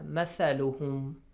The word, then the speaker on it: مثلهم: مثلهم Fawad Haider, the first important point is the pronunciation of the letter. – Tha. – Tha. Mathaluhum. All right? – Yes.